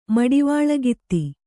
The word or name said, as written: ಮಡಿವಾಳಗಿತ್ತಿ